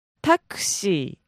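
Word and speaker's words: Taxi (タクシー): Taxi. – Taxi. – Mm-hmm. – So, emphasis on the ta. – Mm-hmm. – Taxi. – Yes. – And you got to hold that.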